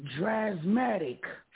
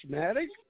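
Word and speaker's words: Drazmatic: Trashmatic? What? – Trashmatic. He called him Trashmatic. – I thought you said Trashmatic. – No, Dras. How you spell it? D-R-A-S? – D-R-A-Z. – Oh, D-R-A-Z.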